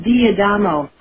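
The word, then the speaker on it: D'Adamo: First name Peter, last name D, apostrophe Adam O, like Adam with an O on the end. – It's pronounced Diadamo. – Yes, something like that.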